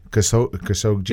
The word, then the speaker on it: Khashoggi: Khashoggi. – It's the hard G, then the soft, like J, so Khashoggi.